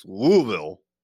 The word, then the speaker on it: Louisville: It's not Louisville, for sure. I don't think it's Louisville. It's Louisville. Two syllables. There's a lot of L's in there, and you kind of just slur the words.